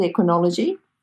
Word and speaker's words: equinology: Now, this equinology equine body worker, is that how you pronounce it, equinology? – Yeah, that's correct.